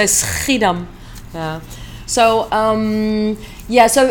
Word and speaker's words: Schiedam: a little bit about I never pronounce it right. Schiedam, is that right? – Schiedam.